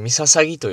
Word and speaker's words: 陵: えー、天皇陵と言いますと、まあ、三笹木、えー、陵という字はですね、三笹木と読みますね。